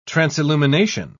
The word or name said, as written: transillumination